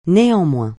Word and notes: néanmoins: francais

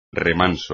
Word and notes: remanso: espagnol

